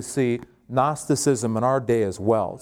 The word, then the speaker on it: Gnosticism: Gnosticism. Now that's kind of a fancy word. Think of it like this: Gnostic with ism after it. And Gnostic is spelled G-N, Gnostic.